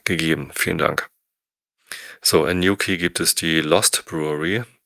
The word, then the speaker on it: New Quay: Auch das wird anders ausgesprochen, als ich dachte. New Quay, vielen Dank nochmal.